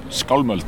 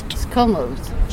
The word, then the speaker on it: Skálmöld: Skálmöld. – Skálmöld.